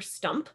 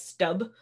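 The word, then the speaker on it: Stumpp: Sometimes you'll even see it spelled or said as Stub. But often he was referred to as Peter Stump.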